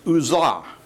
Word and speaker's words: Gaza: The word is pronounced Uzzah in Hebrew, and it comes from the verb that means to be strong. So there is a real irony here.